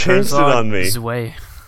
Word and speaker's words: Herzog Zwei: So sit back, relax, and enjoy episode 55, Herzog, Why? – Wait, what? – Overview. – I think you're mispronouncing it. I think it's Herzog Zwei.